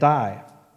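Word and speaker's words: Die (δεῖ): D-E-I is how we'd spell it. Die.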